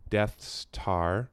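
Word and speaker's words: Deth's Tar: So it's supposed to sound like Death Star, but they spell it D-E-T-H.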